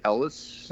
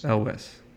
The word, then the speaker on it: Elwes: It's Cary Elwes. – Elwes. – Elwes. Elwes. Elwes. Elwes. – Cary Elwes. – Yeah. So was Joker a better dime store Val Kilmer's Doc Holliday or Cary Elwes? – He's not.